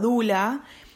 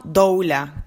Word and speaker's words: doula: la palabra dula, que proviene del griego y en griego se pronuncia bastante parecido a dula, eh, se toma en el inglés y se pronuncia así, dula. Sin embargo, en España y en castellano, creo que en la mayoría de Latinoamérica también, se usa el término doula, que es como se escribe la palabra, D-O-U-L-A.